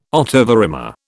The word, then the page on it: ottava rima: British English